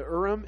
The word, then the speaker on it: Urim: And they Ummim and Thummim. And how do you say that again? The Ummim and Thummim. Thummim. The Urim and Thummim.